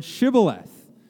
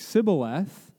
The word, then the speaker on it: shibboleth: they would make them say the word shibboleth. And if they pronounced it wrong, like sibboleth, then they knew that these people were foreigners and proved to not be a part of their tribe.